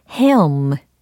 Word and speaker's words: helm: helm. 이 발음 기억나세요? helm. 예, 그래서 H-E-L-M 이라는 철자입니다. 마치 헤엄을 치듯이. 하지만 헤엄은 아니고 헤엄, 헤엄. 이렇게 발음이 됩니다. 그래서 키를 조종하다, 지휘하다.